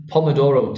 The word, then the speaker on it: Pomodoro: This is the Pomodoro technique.